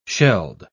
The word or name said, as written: shelled